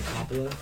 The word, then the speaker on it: Coppola: Pronounced Francis Ford Coppola.